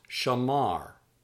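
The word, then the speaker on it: shamar (שמר): Word 41, meaning to keep or protect, is pronounced shamar, shamar, shamar.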